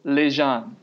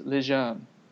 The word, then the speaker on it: Lejeune: Lejeune, yes. Seriously, that's how it's pronounced, apparently. We confirmed it with FSU side. Lejeune. I went and I listened to like a French dialect thing like 3 or 4 times today to be able to say it correctly. It's Lejeune. So Lance Lejeune.